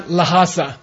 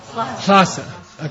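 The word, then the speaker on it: Lhasa: You reverse the H and the L, right? Hlak. Okay. Like, and it's not Lahasa. It's Hlasa.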